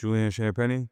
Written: Julian Champagnie

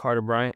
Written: Carter Bryant

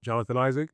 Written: Jonathan Isaac

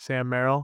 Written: Sam Merrill

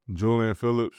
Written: Julian Phillips

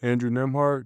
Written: Andrew Nembhard

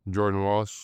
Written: Jordan Walsh

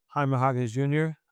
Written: Jaime Jaquez Jr

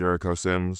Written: Jericho Sims

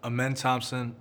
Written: Amen Thompson

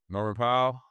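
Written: Norman Powell

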